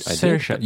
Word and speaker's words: Saoirse: I keep forgetting how we pronounce her name. Shara Ronan. Guys, Saoirse.